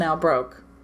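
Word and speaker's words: Nábrók: It is spelled N-A-B-R-O-K, and it has the little asterisks over the A and the O. I mentioned it briefly in last episode, but I absolutely mispronounced it. But upon looking it up further, it is pronounced Nabrok.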